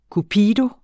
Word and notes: cupido: kuˈpiːdo

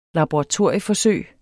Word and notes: laboratorieforsøg: [ lɑboʁɑˈtoɐ̯ˀjə- ]; [ labo- ]

